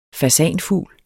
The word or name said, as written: fasanfugl